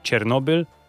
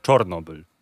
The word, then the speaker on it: Czornobyl: a mianowicie teraz już nie czekamy na e, Stalkera 2 e, Heart of Chernobyl, tylko na e, Stalkera 2 Heart of Czornobyl.